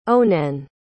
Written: onenn

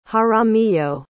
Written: jaramillo

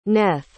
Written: Neth

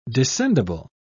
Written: descendible

